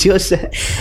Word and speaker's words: Yosemite: It's Yosemite.